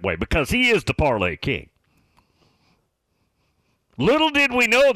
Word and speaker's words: Parler: P-A-R-L-E-R, pronounced parlay. According to Buckeye Brenda. Actually, I was looking at it over the weekend, and I went to a site, and it said it is pronounced parlay.